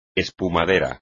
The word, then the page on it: espumadera: espagnol